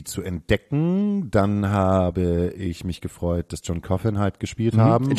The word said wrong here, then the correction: John Coffey: John Coffey im Übrigen. – John Coffey. Ich habe mir gestern gesagt, das wird John Coffin ausgesprochen. – Auf keinen Fall, John Coffey.